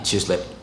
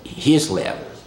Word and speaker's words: Chislev: I'm not Chislev, Hislev. H-I-S, Hislev. Because the C in Hebrew is silent.